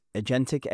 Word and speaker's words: agentic: And what I read is agentic AI. Am I right? Am I saying that right? – Yes.